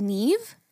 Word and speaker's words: Niamh: is N-I-A-M-H. And by the way... – She said how to pronounce it as Neve? – That's how you pronounce it.